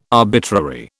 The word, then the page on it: arbitrary: British English